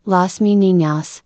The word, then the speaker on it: Las Meninas: Las Meninas, Spanish for the ladies-in-waiting pronounced, Las Meninas,